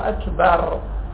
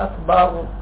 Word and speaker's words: أكبر: تنشوفوا الله أكبر، الراء هكذا تلفظ. أكو البعض من الناس وما أكثرهم في العراق يقول الله أكبر، ما يقدر يتلفظ الراء بشكل صحيح، يقول صلاة الإشكال.